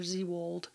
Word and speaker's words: Zwoyld: I've pronounced it Z-World before Z-World. It's actually spelled Z-W-O-Y-L-D. And that was the song Sank, S-A-I-N-K.